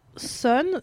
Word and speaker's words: Sohn: Donc mon kiff est une BD d'une autrice qui s'appelle Lily Sohn, j'ai envie de le dire à l'allemande parce que ça s'écrit S-O-H-N, Lily Sohn.